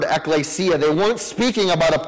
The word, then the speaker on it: ekklesia: When New Testament writers used this idea in Scripture, they almost always, at least in the New Testament, using the word Greek, used a word that's pronounced ekklesia.